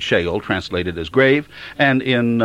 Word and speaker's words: Sheol: The word hell actually is normally translated from an Old Testament word which is called Sheol, or pronounced Sheol in the Old Testament, and in the New Testament there is an equivalent word called Hades.